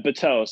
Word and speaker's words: Bateaux: How are we pronouncing it? Bateaux? – Yeah, Bateaux. So it's, you know, two syllables.